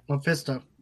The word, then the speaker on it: Mephisto: Mephisto. – Mephisto. Got tongue-tied there. – It's okay. – Could be Mephisto. Could be again, maybe Dwell of Darkness again. Could be... then again, it could be Mephisto because he plays a big part in Spider-Man stories.